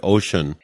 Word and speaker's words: ocean: You realize the word ocean has neither an S nor H in it, but if you had to do it phonetically, you'd do that, right? – Yeah.